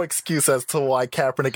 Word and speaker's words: Kaepernick: Kaepernick, not Kaepernick. – I mean, whatever his name is. The guy with the afro. – Can you say his name right? It's Colin Kaepernick.